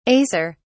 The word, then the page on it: acer: British English